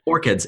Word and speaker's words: orchids: And I get also why you keep referring back in the book to orchards. How do you say the word? Why am I not pronouncing it right? – Orchids.